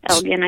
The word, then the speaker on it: Elgin: out there in I believe it's am I saying it right? Elgin. How do you? – Yeah. Elgin, I believe.